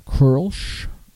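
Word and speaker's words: Kölsch: And Mitch is correcting my pronunciation. It's Kolsch. Kolsch.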